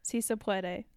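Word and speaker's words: Sí se puede: Si se puede.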